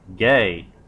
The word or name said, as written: gay